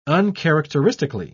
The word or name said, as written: uncharacteristically